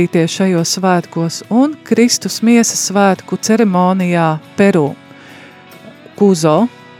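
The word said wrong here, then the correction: Cusco: nezinu, vai pareizi izrunāja, tā ir pilsēta, Kuzo, Kusko vai, jā, Kusko pilsēta Peru.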